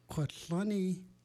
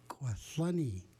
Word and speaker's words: Kwatlani: Kwatlani is how you say happy in Itchishkin. Kwatlani. Kwatlani.